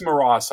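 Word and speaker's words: Moross: The film was called The Mountain Road, and the composer is, hopefully I'm pronouncing this correctly, Jerome Marose. – Actually, it's Maras, I believe. – Maras, excuse me.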